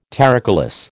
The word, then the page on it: terricolous: American English